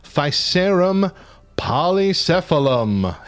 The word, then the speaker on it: Physarum polycephalum: The Blob is also known as Physarum polycephalum.